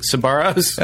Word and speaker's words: Sbarro: I mean, that sounds like Sparrows pronounced incorrectly. – Sbarro's. There's Sparrows. A little bit of a B in there.